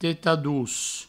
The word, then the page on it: tetar-doç: teta-dous